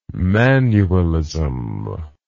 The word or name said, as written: manualism